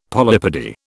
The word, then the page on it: polypody: British English